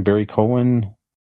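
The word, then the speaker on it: Barry Keoghan: Am I saying his name right? Barry Cohen? – Yes.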